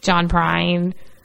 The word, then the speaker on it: Prine: Um, John Prine. Did I say that right? I'm always afraid I said his, I say his name wrong. Sound fine to me.